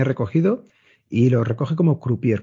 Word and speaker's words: croupier: Eva es croupier, pero creo que lo he dicho mal o no.